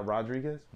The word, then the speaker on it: Rodriguez: Not Rodriguez? – No. – Rodriguez. – Yeah, that's how Brazilians pronounce their R's with H's. That's why it's H's. Like, it's not Roger Gracie, it's Hodger Gracie.